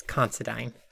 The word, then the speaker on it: Considine: Patty Considine. Considine? – An I and E sound in English is technically pronounced ein, so. – Considine. – Like dine, wine. Yeah, I think it's Considine. So this movie is...